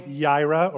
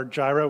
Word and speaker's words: Yireh: This is the, the name of God that we sung about this morning Yahweh Yireh, or Jireh, we sang a different pronunciation, and it means the Lord will provide.